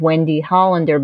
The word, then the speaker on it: Wendy Hollender: My name, which is Wendy Hollender, but you have to spell it W E N D Y, and then Hollender is H O L L E N D E R.